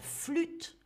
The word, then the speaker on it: flûte: Piano, la guitare, la guitare avec le R, la flûte, hein, le U, très important, la flûte, l'harmonica, l'harmonica avec ce H qu'on ne prononce pas, et attention, la batterie.